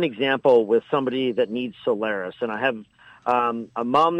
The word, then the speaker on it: Soliris: For example, I'm aware of the fact that a drug, and if I pronounce it wrong, please correct me, Soliris? – Soliris.